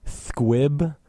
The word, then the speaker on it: Thquib: Our next song is by an artist called Thquib. I believe that's probably how you pronounce it. It's spelled T-H-Q-U-I-B.